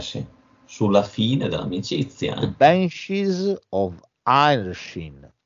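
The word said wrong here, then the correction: Inisherin: The Banshees of Ainisherin. Ecco, mi è venuto.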